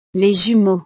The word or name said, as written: les jumeaux